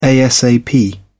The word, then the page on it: asap: British English